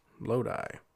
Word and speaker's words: Lodi: The song describes a plight of a down-and-out musician whose career has landed him playing gigs in the town of Lodi, pronounced Lodi,